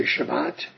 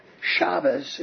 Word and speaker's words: Shabbos: Shabbos is the Ashkenazi way of referring to Shabbat. Shabbat is the Sephardic way of expressing it or saying the word. The Sephardic pronunciation. It's the pronunciation used in Israel, but Shabbos is the Ashkenazi pronunciation.